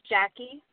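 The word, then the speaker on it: Jacky: My name is Jackie. – How do you spell that? There are so many different spellings. – J-A-C-K, yeah.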